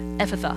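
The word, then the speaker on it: Ephphatha: Jesus said, Ephatha, forgot the last syllable. Ephatha, meaning be opened.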